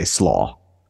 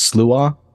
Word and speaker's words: Slough: We used to go by, we would just say Slough, like Slough. Coleslaw. – Oh, but in Gaelic, it's supposed to be pronounced Slua, but I forget what year it was.